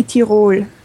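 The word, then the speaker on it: Tirol: Well, in German, it would be Tyrol. – Tyrol. Okay. – But if you pronounce it the English way, that's fine.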